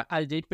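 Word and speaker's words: alt-J: Otras de las bandas más famosas con un símbolo es Al J, que yo tampoco sabía que se pronunciaba Al J, pero técnicamente su nombre es solo una...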